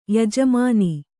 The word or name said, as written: ಯಜಮಾನ